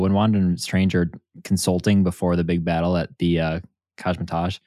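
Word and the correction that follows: Kamar-Taj: Did I say that right? Kosmetosh? I always forget. – Kamertage. – Kamertage, thank you.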